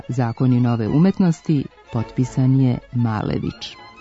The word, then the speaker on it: Malević: Zapravo, on je sada Malević, iako sam ga i ja, kao i vi, tokom školovanja upoznala kao Maljevića.